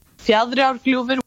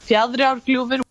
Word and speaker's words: Fjaðrárgljúfur: How did Alex pronounce that name? Fjaðrárgljúfur. Now, Phil. – Bjardsgolf. – Uh, Alex. Fjaðrárgljúfur. Phil. – Yards golf? No. No, a massive fail.